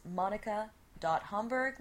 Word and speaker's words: Hamburg: My email is monica.homburg. My last name is spelled H-A-M-B-U-R-G, like the city in Germany. I just like pronouncing it exotically.